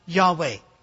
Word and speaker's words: Yahweh: We now know, because we have many more manuscripts, and we've met Jews, that God's name was probably pronounced something like Yahweh.